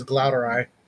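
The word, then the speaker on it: glauerti: is it Glauti? – Yeah, I've always heard it as pronounced as Glauteri.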